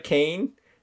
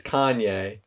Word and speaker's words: Kanye: And then the the lady's like, this is Kane. And he's like, it's Kanye. He's like, oh, well, we pronounce it Kane around here.